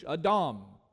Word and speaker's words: Adam: A-D-A-M is how we would spell it in English. Adam. Sounds like Adam. That's where he got his name from.